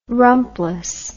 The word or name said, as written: rumpless